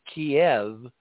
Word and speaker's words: Kyiv: Kyiv. It comes from the fact that Russian and Ukrainian have pretty much the same dictionary, but they have different vowels. They have different vowel sounds. Both alphabets have 33 letters, but three of them are different in Ukraine. – Well, in Russian, Kyiv is Kiev, and as I've been taught by an yes.